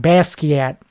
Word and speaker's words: Basquiat: Like that painter guy, Bisco. I think I said it right the first time. Basquiat. That's exactly how it's pronounced.